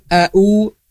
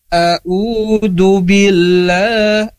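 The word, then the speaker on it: A'udhu billah (أعوذ بالله): Dari awal ta'ud tadi a'u bukan a'u. A'udhu billah, a'udhu billah. Harusnya a'u. Harus ada perbedaan yang kontras atau jelas. Ain dengan Hamzah itu berbeda secara pengucapannya.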